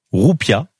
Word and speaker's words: roupia: Ce terme vient du sanskrit roupia, J'ose imaginer que je le prononce. – C'est parfait.